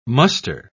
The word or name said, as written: muster